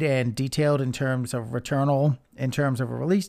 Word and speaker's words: Returnal: The other game they revealed was a game from Housemarque Games called Returnal. I believe that's the way it was pronounced. Basically, it's spelled return with an A-L at the end of it.